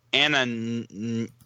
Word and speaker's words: Anamnesis: First off, from Gematsu, we've got a new Starshin game, Starshin Anamnesis. I don't know how to pronounce this. – I would say it's anamnesis.